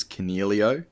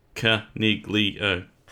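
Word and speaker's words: Coniglio: Now, we have to pull you up on the pronunciation. We have been called out for it. It is Caniglio.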